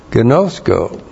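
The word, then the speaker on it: gnosko (γινώσκω): And then, gnosko. G-I-N long O-S-K long O. Gnosko.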